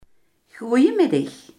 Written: goedemiddag

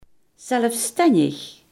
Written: zelfstandig

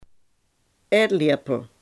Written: eetlepel

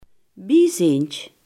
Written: bijziend

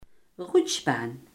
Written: glijbaan

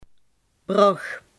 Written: Maasbracht

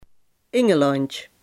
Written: engeland

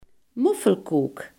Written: dikkoek

